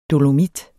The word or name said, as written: dolomit